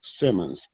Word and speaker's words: Simmons: Steve Simmons. S-I-M-M-O-N-S.